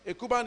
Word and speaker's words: Ekuban: A podcast addict, and just put the name Jonathan Ekuban. Ekuban is spelled E-K-U-B-A-N.